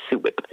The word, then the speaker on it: SUWIP: uh, and that's how they say it, SUWIP.